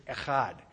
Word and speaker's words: echad: And the word that they've chosen here is the word echad. And if you're going to say it right, you've got to say it from about here. Echad. You guys have seen Ahmed, the dead terrorist, haven't you? A-C-H, phlegm, there it is, Ahmed.